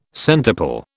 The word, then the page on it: centuple: British English